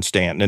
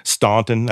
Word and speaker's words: Staunton: As far as our ghost tours here in Stanton, and there's different ways people pronounce it. We always have a thing here. If you say it's Staunton, that's the northern way, and you're not from around here.